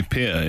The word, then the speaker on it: Pierre: That's how it's pronounced in South Africa. – Is it really? – My family call me Pierre, yeah. – Oh, Frank, that's a new one for us. – Pierre to rhyme with beer.